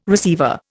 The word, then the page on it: receiver: Indian